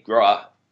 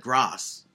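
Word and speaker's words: coup de grâce: On Monday Night Raw, they say gras. – Do they? – Yeah. – I thought they said Gross. – No, they took it. I think it was just who, like, Corey Graves. Someone pronounced the S by accident.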